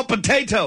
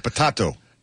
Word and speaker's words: Patato: So is it Patato or Potato? – It's Patato. In Spanish, it's Patato.